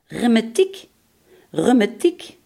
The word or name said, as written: reuma